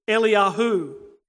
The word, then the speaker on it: Eliyahu: The Hebrew pronunciation for Elijah is Eliyahu. Can you hear the confusion? Eli, Eli, Eliyahu.